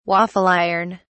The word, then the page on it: waffle iron: British English